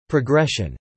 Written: progression

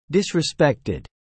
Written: disrespected

